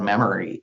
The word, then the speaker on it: Memry: The new company name is Memory Health, and the product is called Memory. And think of M, so it's spelled M-E-M-R-Y.